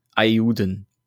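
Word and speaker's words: Eiyuden: Ja, also I E I Y U D E N. Ayuden. Ayuden, hm. wie auch immer man das auf Japanisch ausspricht. – Ayuden.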